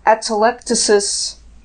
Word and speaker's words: atelectasis: My report said atelectasis.